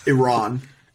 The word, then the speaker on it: Iran: Do you know how to pronounce it? – Iran. – Iran!